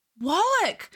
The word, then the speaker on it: Wallach: She's like, Wallach. And he like, I thought it was Warlock. – It's spelled W-A-L-L-A-C-H. – Oh, cool. That's weird. The audiobook narrator said Warlock. – Oh, interesting. In my head, it was Wallach.